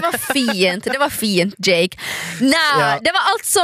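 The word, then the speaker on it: JVKE: ut Golden Hour med, vi snackar om hur det uttalas, Jake ska det tydligen uttalas. – Jake, förstås, för det är ju ett A upp och ner.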